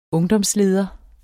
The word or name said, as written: ungdomsleder